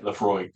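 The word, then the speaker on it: Laphroaig: You know when you buy a bottle of what is it, Lafroy? Is that how you say it? – Lafroy.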